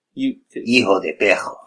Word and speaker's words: Hijo de Perro: And this guy, El Hijo del Perro. – Hijo de Perro. – There you go. Thank you. We're gonna go with that pronunciation.